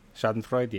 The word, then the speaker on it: Schadenfreude: But that's just Merritt Paulson Schadenfreude. Did I say that right? – Schadenfreude, yeah.